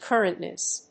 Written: currentness

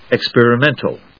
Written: experimental

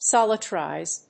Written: solarize

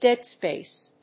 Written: dead-space

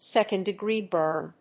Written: second degree burn

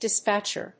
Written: dispatcher

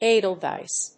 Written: edelweiss

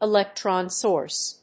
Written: electron source

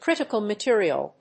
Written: critical material

